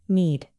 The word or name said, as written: knead